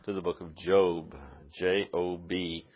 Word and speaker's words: Job: It looks like, but we pronounce it Job.